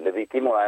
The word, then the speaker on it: Victimula: So che l'Eldorado italiano si trova tra Verbogno e Zubiena, che è proprio in provincia di Biella, nella vecchia miniera di Victimula, ho detto bene? – Sì, Victimula è